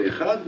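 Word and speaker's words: Echad (אחד): On devra allonger dans la prononciation dans le mot de Echad, mais allonger en particulier le mot de Dalet.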